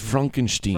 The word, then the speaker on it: Frankenstein: It's pronounced Frankenstein. – Frankenstein, I'm sorry. We'll do our German show.